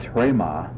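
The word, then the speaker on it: hrema (ῥῆμα): The word for word, basically a synonym with a synonym of logos, is hrema, hrematas. Observe the rough breathing. So it's pronounced as if it had an H in front of it.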